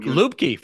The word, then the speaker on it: Lubke: Yeah, almost, but like him and this other guy, Dylan Lube, he's from Lubke from North New Hampshire. – You know, yeah, that's how you pronounce his name.